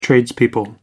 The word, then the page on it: tradespeople: American English